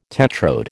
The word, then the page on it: tetrode: American English